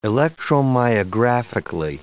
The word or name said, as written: electromyographically